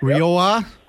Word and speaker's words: Rioja: Rioja? Is that the correct pronunciation there? – Yeah. Yeah, say a ha. They're Rioja.